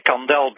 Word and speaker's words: Kandel: Is your last name pronounced Kandel, like the candle that burns? – Well, whatever you say, I say Kandel, but whatever you say is fine.